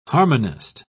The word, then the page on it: harmonist: British English